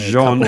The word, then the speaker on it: Jean-Jacques Rousseau: Today you are talking about, apparently, Jean-Jacques Rousseau. – That is not how you pronounce it.